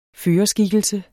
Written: førerskikkelse

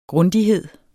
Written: GRUNDIGHEDEN

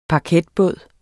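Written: paketbåd

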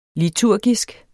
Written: liturgisk

